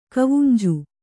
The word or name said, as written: ಕಾವುಂಜಿ